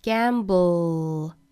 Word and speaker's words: gamble: gamble 这个词我们看到尾部舌侧音，舌尖抵住上齿龈之后再去发后面的 l 的发音， gamble， gamble， gamble。也就是这个单词都发完整了，舌尖依然是抵住上齿龈的位置，不要把它弹下来，不是 gamble，是 gamble。